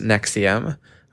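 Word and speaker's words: NXIVM: It's spelled like Nxium and it's pronounced Nexium.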